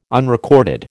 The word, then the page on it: unrecorded: American English